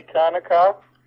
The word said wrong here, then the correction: Hanukkah: I don't believe it's pronounced Kanukkah, is it? That sounds like a gas station. – Oh, I don't know. Is it? – Yeah, I thought it was Hanukkah.